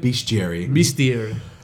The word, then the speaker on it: bestiary: I'm pretty sure I'm saying this wrong. Bestiary. That's what you said. – Bestiary. A very good one too.